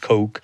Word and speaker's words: Koch: By the way, Coke, K-O-C-H, it is pronounced Coke, is the name of the Coke family, the second wealthiest family in the United States after the Waltons, who own Walmart.